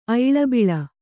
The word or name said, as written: ಐಳಬಿಳ